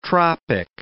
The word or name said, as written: tropic